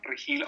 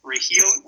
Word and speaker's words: Regil: I have a podcast coming up, but all the information you can find is on Marco Antonio Regil on Facebook. Or Instagram is the same name, Marco Antonio Regil, which is spelled R-E-G-I-L, and I'm posting in English and Spanish.